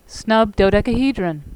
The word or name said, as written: snub dodecahedron